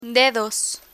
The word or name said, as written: dedos